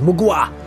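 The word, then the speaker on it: Mgła: Age of Excuse staves M, G, en L, en sånn polsk L med en strek gjennom, og A. Okay. – Men det uttales Mogwa.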